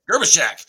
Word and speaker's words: Gerbyshak: Gerbyshak.